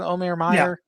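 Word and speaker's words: Omer Mayer: No, is it Meyer? Is that how you pronounce it? Omer Meyer? – Yeah. Yep.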